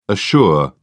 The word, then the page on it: assure: British English